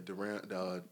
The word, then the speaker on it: Deron: Is it Deron? How you say his name? – Yeah, Deron.